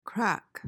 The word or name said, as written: craic